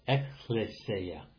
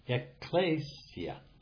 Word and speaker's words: ecclesia: I learned to pronounce the word English-wise, and now to turn around and pronounce it in the Greek, I sometimes have to stop and think about it. Ecclesia. You'll hear it pronounced in the English, ecclesia, but it's ecclesia.